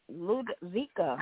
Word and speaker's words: Ludovica: Ludovica.